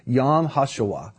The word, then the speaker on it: Yom HaShoah: It's actually pronounced Yom HaShoah.